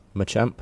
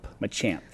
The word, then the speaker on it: Machamp: Mine's a Manchamp. – Machamp? – Machamp, thanks.